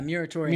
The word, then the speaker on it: Muratorian: We have what is called the, I might say this incorrectly, the Muratorian fragment. – Yeah, Muratorian. – Muratorian, that's good.